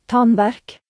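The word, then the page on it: ache: Norwegian Nynorsk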